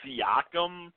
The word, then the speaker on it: Siakam: I believe his last name is pronounced Siakam.